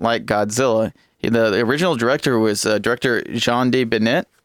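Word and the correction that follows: Jan de Bont: Wait, I said that wrong. Jean de Bont. Wow, murdered his name.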